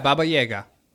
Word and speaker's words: Baba Yaga: Is it Baba Yaga? Did I say it right? – Yeah, Baba Yaga.